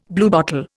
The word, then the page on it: bluebottle: Indian